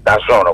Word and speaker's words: DAZN: Τους έβγηκε η Dazn, τα ζώνα όπως προφέρεται, και τι κάνανε, δημιουργήσαν το δικό τους υβριδικό κομμάτι.